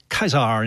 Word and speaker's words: Caesar: Like in Caesar, you know, it's pronounced Kaiser in